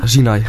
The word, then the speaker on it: Zinai: Want nu word je opgevolgd door Zeni Rosalina. – Zinai Rosalina. – Zinai, moet ik zeggen.